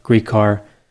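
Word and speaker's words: Gricar: I've seen Grecar, Grecar, Grecar, but from what I understand, Grecar is the way he pronounced his name.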